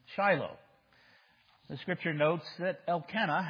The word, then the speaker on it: Elkanah: But we're pronouncing her name Peninnah, if we're consistent, and his name Elkanah.